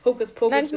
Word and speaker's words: Hokus Pokus: Ich glaube, in so Kinderbüchern wird doch auch voll oft so Hokus Spokus. Jetzt habe ich es selber falsch gesagt. Hokus Pokus gesagt.